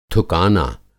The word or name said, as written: थुकाना